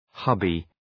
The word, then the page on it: hubby: ‘hʌbı